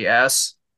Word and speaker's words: Haas: Even like Viggo Mortensen had a small appearance of Lucas Haas or Hayes, however you pronounce his last name, Haas.